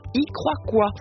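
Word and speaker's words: Icroacoa: Et puis voici le message de Mathieu, qui nous présente l'association Y Croix Quoi. J'aime beaucoup ce nom, ça s'écrit I-C-R-O-A-C-O-A, Y Croix Quoi.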